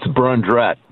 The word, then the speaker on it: Brundrett: It's Brundrett.